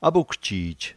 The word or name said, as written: apukjij